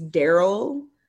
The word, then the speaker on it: Daryl: The way you pronounce my name is Daryl.